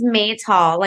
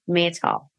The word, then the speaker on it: Maytal: The name is Maytal, like the month of May and tall. Maytal.